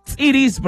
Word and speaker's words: tziris: Sí, tziris, sí, es tziris, porque sí tiene su apóstrofe.